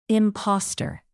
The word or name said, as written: imposter